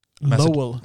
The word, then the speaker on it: Lowell: Ja, staden som The Fighter utspelade sig i var Lovell Lowell. Lowell? Tror man Lowell, ja.